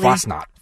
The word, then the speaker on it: Fasnacht: Fasnacht, am I saying it correctly? – Fasnacht. – Fasnacht? – Yeah, not hard knocks.